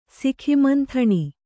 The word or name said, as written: ಸಿಖಿಮಂಥಣಿ